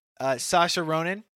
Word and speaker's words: Saoirse: Three Billboards, Margot Robbie from I, Tonya, Saoirse Ronan from Lady Bird, and Meryl Streep.